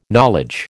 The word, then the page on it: knowledge: American English